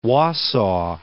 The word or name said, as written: Wausau